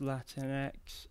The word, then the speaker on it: Latinx: I believe it's pronounced Latinx.